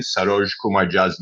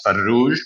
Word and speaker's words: Saroj Kumar Jha: And he, he did this sort of uh, seemingly racist, uh, you know, uh, repeatedly saying, uh, you know, mispronouncing Saroj Kumar Jha's name as Farruj, which means rotisserie chicken, or Marrush, which is kind of like an, a nonsense word.